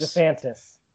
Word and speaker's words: DeSantis: Is that how you say his name? DeSantis? – DeSantis.